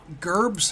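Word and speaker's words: Gerbes: Gerbs.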